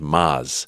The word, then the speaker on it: Maz: To go, listen, man, not a big deal, but just it's actually pronounced Maz. – Right. – And then I go through the whole thing. I go, it's like Mazda, Mazatlan, Maserati, La Maz.